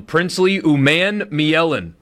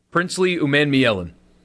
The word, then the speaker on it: Umanmielen: Princely Umanmielen. Umanmielen. Umanmielen. – Well done. You're not going to feel like you need to delete that pronunciation. – No, I think I got it. Well done. I think that is absolutely the correct one.